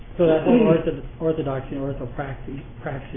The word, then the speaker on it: orthopraxy: And part 1 is orthodoxy, and part 2 is orthopraxy.